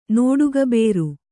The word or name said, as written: ನೋಡುಗಬೇರು